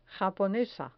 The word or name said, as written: japonesa